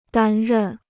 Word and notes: 担任: dān rèn

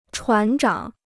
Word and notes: 船长: chuán zhǎng

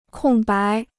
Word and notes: 空白: kòng bái

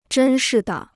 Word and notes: 真是的: zhēn shi de

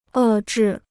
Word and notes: 遏制: è zhì

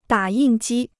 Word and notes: 打印机: dǎ yìn jī

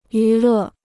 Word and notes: 娱乐: yú lè

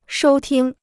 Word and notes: 收听: shōu tīng

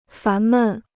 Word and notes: 烦闷: fán mèn

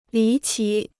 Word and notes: 离奇: lí qí

